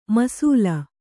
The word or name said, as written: ಮೀಸಲು